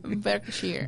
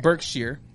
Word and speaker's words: Berkshire: Berkshire. – Berkshire.